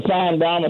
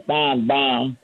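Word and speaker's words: Finebaum: Hey, Mr. Feinbaum. It's always an honor to be on your show. I should say Feinbaum.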